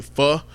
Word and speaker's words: pho: So if you're saying pho, then that's not the pronunciation of it. It's actually pho.